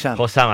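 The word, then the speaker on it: Hossam: Se llama Nosam. Josam, así se pronuncia.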